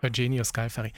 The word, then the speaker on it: Eugenio Scalfari: Der Papst hat dem italienischen Journalisten Eugenio Scalfari. – Ah, habe ich am Anfang falsch gesagt, okay. – Finde ich aber nicht so schlimm. – Eugenio Scalfari.